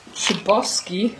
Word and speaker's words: Chbosky: Also letzte Woche saß ich ja hier und ich hatte leider nichts zu dem Buch The Perks of Being Wallflower von Stephen Chbosky, wird er glaube ich ausgesprochen. C-H-B-O-S-K-Y. – C-H-B finde ich schon schwer zu sprechen.